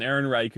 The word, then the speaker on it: Ehrenreich: There's another nod I want to give to Alden Ehrenreich. Is that how you say his name? – Ehrenreich.